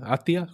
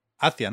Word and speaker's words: Athia: Atia, ¿cómo se, pronuncia exactamente? Hacia, ¿no? Supongo. – que Sí. – A-T-H-I-A, que es un mundo fantástico, un reino mágico.